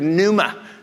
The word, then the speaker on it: pneuma: It's the word pneuma, spelt with a P, where we get our word pneumatic.